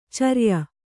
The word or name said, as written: ಚರಿಯ